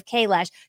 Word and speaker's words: Kailash: Right, so I screwed things up beforehand and I was saying K-dash instead of K-lash. So how do you spell that? Where is this again? – It's spelled K-A-I-L-A-S-H, Mount Kailash.